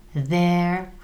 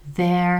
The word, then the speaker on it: their: Jejich domy, v písmu je íčko, ale to se nečte, their house, houses, their houses, they, about them, their houses.